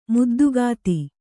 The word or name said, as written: ಮುದ್ದುಗಾತಿ